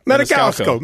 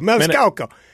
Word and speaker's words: Maniscalco: How do you say his name? – Maniscalco. – Maniscalco.